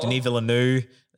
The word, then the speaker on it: Denis Villeneuve: Denis Villeneuve, that's how you say it.